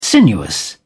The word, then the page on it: sinuous: British English